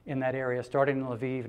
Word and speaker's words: Lviv: This is Masha and her husband Doug and their children, and uh, they are missionaries in I think it's pronounced Lviv, L apostrophe V I V.